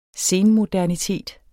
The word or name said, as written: senmodernitet